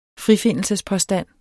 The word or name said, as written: frifindelsespåstand